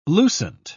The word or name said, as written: lucent